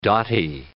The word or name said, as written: dotty